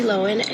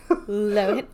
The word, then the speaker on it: Lohan: Okay, but did you hear how she said her name just there? – Yeah, I said it wrong. Yeah. – We've all been saying it wrong. – Lohan. – We've literally all said it wrong for the past 20 years.